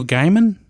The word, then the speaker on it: Gaiman: Neil Gaiman.